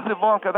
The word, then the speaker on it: Ivanka: I know you don't support President Trump, but this is for his daughter, Ivanka, or Ivanka, Well, it is Ivanka. That's how the family pronounces it.